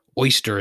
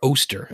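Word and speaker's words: Oester: Maybe Jeff Treadway or Ron Oyster is available. Is it Oyster or Oster? I think it's just pronounced Oster. 1990 World Series champion, Ron Oster, Cincinnati Reds.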